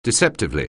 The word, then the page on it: deceptively: anglais